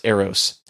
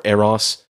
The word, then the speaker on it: Eros: The next one we're going to talk about is Eros, and I think a lot of people know what Eros is when they hear it, or Eros, however you pronounce it, E-R-O-S,